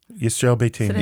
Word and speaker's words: Yisrael Beiteinu: Yisrael Beiteinu.